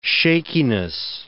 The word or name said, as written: shakiness